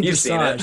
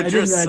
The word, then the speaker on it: dressage: I think you mean dressage. Sorry, I'm a huge fan. – Oh, dressage. – The mispronunciation threw me off.